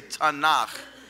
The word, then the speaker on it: Tanakh: When I say Tanakh, you should disregard the vowel sounds, because if I were to write Tanakh out in English, I would probably spell it T-A-N-A-C-H. But because in Hebrew we're concerned more with the consonants than the vowels, you need to just hear the T sound, the N sound, and the H sound, which don't practice because I don't want you to hurt yourselves.